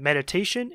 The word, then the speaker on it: meditation: So the first one is meditation. Put more stress on the third syllable. Meditation. You hear the te, te. It's a little bit stronger.